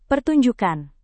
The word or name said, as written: pertunjukan